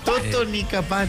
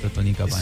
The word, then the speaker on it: Totonicapán: Totonicapán. – Totonicapán. – Sí, Totonicapán.